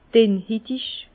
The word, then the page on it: dennhitig: Bas Rhin; Herrlisheim